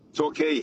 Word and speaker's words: Torquay: Torquay. – Torquay. – Torquay. Yeah. It's spelt Talk on my screen, like motor talk, but I thought, it might be Torquay, but okay.